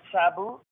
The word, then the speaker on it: Sabu: Now, my first name, S-A-B-U, at... – All right. It's, and am I saying this correctly, Cebu? – Right, Cebu.